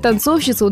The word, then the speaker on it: танцовщица: Да, в слове «танцовщица» ударение падает именно на второй слог, и практически все современные словари отмечают, что «танцовщица» с ударной «и» грубая ошибка.